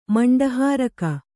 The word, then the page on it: ಮಂಡಹಾರಕ: maṇḍa hāraka